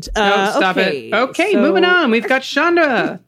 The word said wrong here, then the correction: Shanda: Actually, it's Shanda. Rhymes with panda.